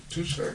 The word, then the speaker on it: Touchet: Are you just saying it wrong? – T-O-U-C-H-E-T. – Touche. – That's Touche.